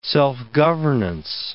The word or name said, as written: self-governance